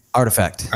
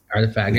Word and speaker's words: RTFKT: In 2021, they bought a NFT pioneer studio called RTFKT Studios. And Artifact. – Okay. – It's spelled funky. – Yeah. – RTFKT. – Artifact.